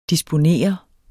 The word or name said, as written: disponeret